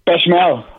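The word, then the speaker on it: béchamel: Bechamel? Is that how you pronounce it? What's it called? – Bechamel. – Bechamel.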